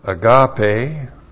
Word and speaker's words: agape: and that is agape, A-G-A-P, long E.